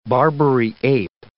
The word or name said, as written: Barbary ape